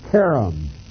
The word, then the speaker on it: cherem: C-H-E-R-E-M, and it's pronounced carom. almost like a K in the front. It's that guttural sound.